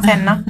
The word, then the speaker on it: Senna: È Senna si chiama, Senna, corretta la pronuncia, fantastico, con cui il record di ben 6 vittorie.